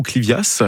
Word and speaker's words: Clivia: Il nous dit, j'ai un Clivia ou Clivias, alors je sais pas comment on prononce. – Clivia, on prononce pas le S.